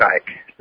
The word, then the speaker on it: Icke: And after that, after lunch, we're actually having David Icke It is Ike, right? – Yeah. – Everybody keeps Ike. – Mispronouncing that, and there we go. It's Ike, it's Ike, and everybody's arguing.